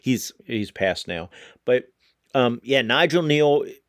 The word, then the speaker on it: Kneale: But that theory is called the Stone Tape theory mostly because of this British show by Nigel Neal. I might be saying that wrong. K-N-E-A-L-E.